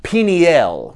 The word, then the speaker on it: Peniel: Peniel would be the pronunciation, because El is the Hebrew word for God, E-L.